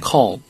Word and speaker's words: call: 요것도 a지만 거의 발음이 어 발음입니다. 네. call.